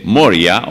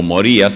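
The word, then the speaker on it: Moriah: En el monte Moria o Moría, según como usted lo pronuncia, con H final, es el lugar donde estaba el templo de Salomón, donde hoy está la mezquita Omara.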